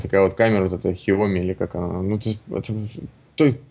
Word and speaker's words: Xiaomi: Вообще, я сейчас считаю, что Sony лидеры на рынке, и никакие Canon'ы, там, Хуями, или как там, Хуями, вообще ни в сравнении нет. Ну, я не помню, как эта фирма правильно произносится. – Xiaomi?